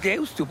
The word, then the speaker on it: Deusto: Es Deusto, porque se escribe con U final.